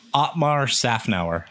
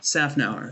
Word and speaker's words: Szafnauer: Uh, Otmar, how do you say his last name? Because I always butcher it. – Otmar Szafnauer. – Szafnauer.